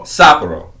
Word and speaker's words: Sapporo: Uh, Sapporo. – Sapporo.